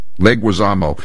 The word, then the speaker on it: Leguizamo: Who is this guy, the star of The Odyssey, John Leguizamo? Is that how you pronounce it? – Leguizamo.